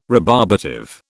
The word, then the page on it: rebarbative: British English